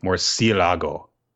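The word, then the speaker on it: murciélago: All in good fun, guys, but seriously, it's pronounced more like Morcilago.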